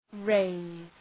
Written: raise

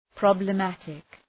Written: problematic

